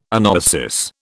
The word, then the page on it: anoesis: British English